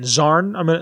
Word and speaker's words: Xarn: Goros, Zarn, X-A-R-N, Zarn, that's how I'm going to pronounce it.